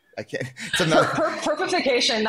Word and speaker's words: perpification: Like one thing you said was in an interview was tokenization's overhype, perpivization is underhype. Did I say that right? – Perpification.